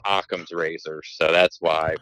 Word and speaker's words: Occam's razor: I used to say Ocams, or, you know, that's basically how I pronounced it, but I was told numerous times it's pronounced Occam's razor.